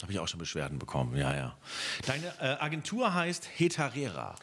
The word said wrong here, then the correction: Hetera: Habe ich das richtig ausgesprochen? – Nein. – Heterera. – Hetera.